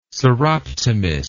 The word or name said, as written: soroptimist